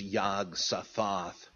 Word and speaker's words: Yog-Sothoth: Well, I think there that it probably is Yog-Sothoth, with the last syllable accented,